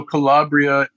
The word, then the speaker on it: Calabria: And so is your family from, and make sure I'm pronouncing this right, Calabria? – Yeah, so Calabria is one branch of my family.